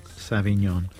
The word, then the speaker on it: Sauvignon Blanc: Sauvignon Blanc? – Sauvignon.